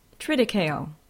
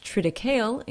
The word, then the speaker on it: triticale: It's pronounced triticale in Great Britain or triticale in the United States.